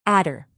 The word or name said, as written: adder